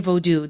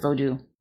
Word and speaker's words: Vodou: And how the sort of knee-jerk white people reaction, the culture of whiteness's response to, how do we say it? Vodou? – Yeah. I say Vodou. There's, you know, a bunch of different ways to say it, but you're good with Vodou.